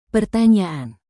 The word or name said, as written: pertanyaan